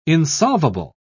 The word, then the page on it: insolvable: British English